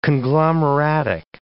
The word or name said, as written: conglomeratic